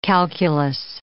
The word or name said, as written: calculus